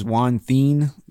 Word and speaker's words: Juan Then: Juan Then, I heard Kay pronounce his name as Juan Thien, but I don't know. It's spelled Then, T-H-E-N.